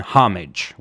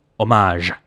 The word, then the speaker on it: homage: I do not use the English pronunciation homage, which is more common for this feudal act. I use the pronunciation traditionally associated with artistic homage, spelled with two M's. Why? Because both words come from French, and in French, it's homage.